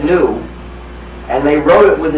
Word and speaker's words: GNU: Anyway, when it's the name of our operating system, please pronounce the hard G. Pronounce it GNU. Say it 1, 2, 3, GNU. Because if you call it the new operating system, you'll get people very confused.